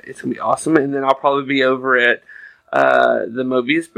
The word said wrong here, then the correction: Modiphius: booth. Did I say that right? – If you're talking about VR, that would be the Modiphius booth.